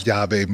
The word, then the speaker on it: Yave: So, and then, but we gave, the way you pronounce Yave means key in Spanish.